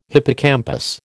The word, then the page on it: hippocampus: American English